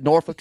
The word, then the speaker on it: Norfolk: Is that how you pronounce that? – We don't drink. We don't cuss. Norfolk.